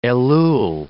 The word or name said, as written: Elul